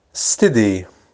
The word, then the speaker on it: steady: steady steady steady. اس ابتدایی رو اس تلفظ نمی کنیم، قبلا هم گفتیم steady سه ساکن ترجیحاً.